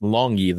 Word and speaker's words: Langi: The two guys to watch for are rookies, Dietrich Weiss, who they drafted in the middle rounds, and Harvey Langi, Longi, L-A-N-G-I, I believe it's pronounced Longi, though, an undrafted free agent, have been playing in that spot and impressing in camp.